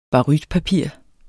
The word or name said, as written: barytpapir